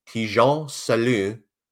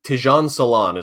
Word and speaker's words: Tijan Salon: So I start here with, I think when I heard this last time I watched film, the French broadcaster said, Tijan Salon is number 16, lovely. I think is the exact pronunciation of his name. – Très bien. – Tijan Salon is probably how the Americans are going to say that one. Number 17. yeah, right. Any English speaker, I should say.